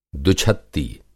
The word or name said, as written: दुछत्ती